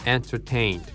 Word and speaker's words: entertained: Entertained って単語ちょっと長いね。なのでね、ストレスが 2箇所ありますね。 Hey、なんかこうちょっと2 拍で、ね、発音するようなイメージだね。まあとはいってもね、この Major Stress 一番こうね、はっきりと発音するのは Tain だね。 Tain、ね、後半の方ですね。だけど前半にもちょっとリズムがあるというふうにイメージしてみましょう。